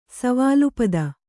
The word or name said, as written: ವಿಲುಪ್ತಿ